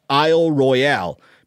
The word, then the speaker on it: Isle Royale: He said it's common for our crew and others to mess up by combining a French pronunciation with an English pronunciation. Here's what he means. If you want to make it French, you call it Isle Royale.